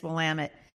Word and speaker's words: Willamette: Is that how you say it? – Yes, Willamette.